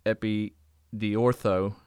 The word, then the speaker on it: Epidiortho: And you gave us that Greek word, and I'm gonna butcher it. Epidiortho. – That's right.